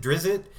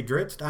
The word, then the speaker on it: Drizzt: That's how they're pronouncing it. I know, a lot of people pronounce it Drizzt. – All my life I've said Drizzt, but they're Wizards of the Coast. They should know what it's their property. They say Dritz. I'm going with Dritz.